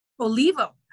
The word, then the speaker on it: Olivo: Olivo, actually.